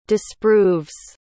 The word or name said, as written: disproves